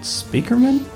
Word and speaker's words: Spiekerman: And finally, Laura, who I've known forever and still won't say her name right, Speakerman? – That's right.